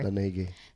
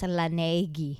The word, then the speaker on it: Laneige: Because I had a moisturizer for like the nighttime, which is Laneige. I don't know how you say that. Laneige or whatever. – Laneige. – So, basta, it's spelled as Laneige. – Laneige.